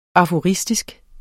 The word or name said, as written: aforistisk